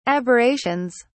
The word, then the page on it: aberrations: British English